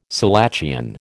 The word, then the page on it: selachian: American English